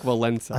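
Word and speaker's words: Wałęsa: If I've ever said a word correctly in my life, it was Lech Walesa.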